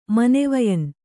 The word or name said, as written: ಮನೆವಯನ್